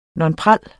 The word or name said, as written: nonpareille